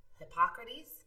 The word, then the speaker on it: Hippocrates: Hippocrates? – Oh, sure. – Is that how you say it? – Yeah. Okay. Like the Hippocratic Oath.